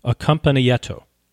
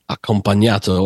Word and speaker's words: accompagnato: S-E-C-C-O? – Correct. – And then accompagnato. – Accompagnato or accompanied recitative. – Accompagnato. We've got a subject matter expert. – Well, I see. – Setting us all straight on the correct pronunciation.